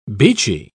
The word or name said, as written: beachy